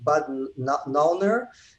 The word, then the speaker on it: Bad Nauheim: It's, I think, Bad Nauheim, and it's near Köln.